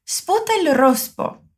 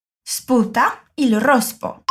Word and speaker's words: sputa il rospo: Sputa il rospo. Sputa il rospo. Presta bastante atenção na pronúncia desse S, que não tem nenhuma vogal antes dele, e na pronúncia do R vibrante.